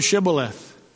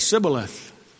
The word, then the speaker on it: Shibboleth: Pronounce the word Shibboleth. And the Ephraimites couldn't do that. They said Sibboleth.